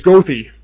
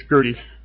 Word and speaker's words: Goethe: When I was drinking, it was Goethe, but it's Goethe.